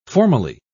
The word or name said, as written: formally